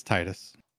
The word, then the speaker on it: Titus: But is it Titus or Titus? – It's Titus.